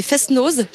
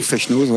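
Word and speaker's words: fest-noz: Vous avez les Fesnoz, j'ai bien prononcé, c'est juste? – Ouais, oui. Ou Fesnoz, ouais.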